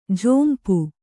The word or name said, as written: ಝೊಂಪು